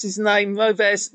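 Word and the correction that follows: Roëves: the guy that plays Stotts. – Oh, Maurice Reeves.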